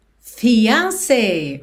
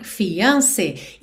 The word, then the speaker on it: fiancé: Durante esse período em que eles estão noivos, se a gente quiser falar meu noivo ou minha noiva, vamos usar my fiancé, ok? My fiancé. E não se preocupe se essa pronúncia não soar natural, porque ela realmente não soa como uma palavra inglesa, ok?